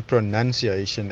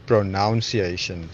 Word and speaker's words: pronunciation: So according to him, and I googled it as well, you say pronounce, but then you say pronunciation and not pronunciation. So that's apparently the correct way of saying it, pronunciation and not pronunciation.